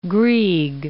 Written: Grieg

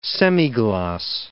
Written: semigloss

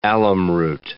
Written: alumroot

